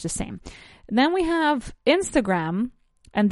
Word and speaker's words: Instagram: We say Instagram, el acento o el stress, ¿no? La acentuación recae sobre la primera sílaba, Instagram, right?